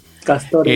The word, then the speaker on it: Castore: con una compañía de nombre Castor o Castore, no sé cómo se pronunciaría, creo que Castore. – Castore. – Eh, sí, Castore, ¿verdad? – Es.